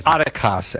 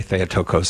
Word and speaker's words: Theotokos: Theotokos, as my Greek professor said. There's a fight over how you pronounce that, but most people say Theotokos, so I just go with what most people wrongly say.